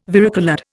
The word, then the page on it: varicoloured: Indian